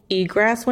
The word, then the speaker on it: egress: So there was no egress windows.